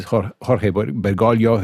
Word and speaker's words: Jorge Bergoglio: This is Jorge Bergoglio, who is, of course, now the current pope.